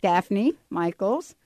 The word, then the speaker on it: Daphne Michaels: Daphne Michaels.